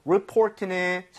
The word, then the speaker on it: report: 그리고 report는 사실 리포트, 레포트, okay? So, yeah. – 신고, 보고서가 되겠습니다. Report, 이렇게 발음할 때 내가 아니라 report. – Report. – 요거만 주의해 주세요.